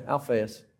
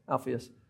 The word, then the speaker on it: Alphaeus: There's James, uh, uh son of Zebedee, and then there's James, uh, son of Alph- Alphaeus, I think is how you say it. Close. And there's even some controversy uh, between that. So some people think that, that, that the son of Alphaeus, that's not how you say the name. I don't know how you say it. Is that right? Okay, Alphaeus. Alphaeus.